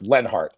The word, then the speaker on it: Leonhart: Now, Lenhart is spelled like Leonhart, L-E-O-N. Hart, H-A-R-T, actually, Leonhart, but it's pronounced Lenhart.